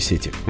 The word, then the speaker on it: сети: Ударение остается на корне, поэтому у нас нет сети, если вдруг она пропала, рыболовная или компьютерная.